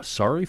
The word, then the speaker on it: saury: Uh, Surrey fishing, uh, sorry fishing, S A U R Y. Pronounce that for me. – Sorry. Sorry.